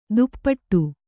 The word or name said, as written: ದುಪ್ಪಟ್ಟು